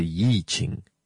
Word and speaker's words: I Ching: known as the I Ching, or you can, when you have to pronounce it technically, you'll read it as the Yi Ching, you know, the one Ching.